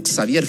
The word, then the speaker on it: Xavier: Probablemente la trascendencia que tuvo lo comía fue el que sostuvo la banda, porque sabemos que era Javier Font, o Xavier Font, si lo quieren pronunciar más en España, el cerebro de la banda, el compositor, y que a su vez era pareja de Manolo Arjona.